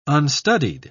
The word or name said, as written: unstudied